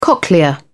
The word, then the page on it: cochlea: British English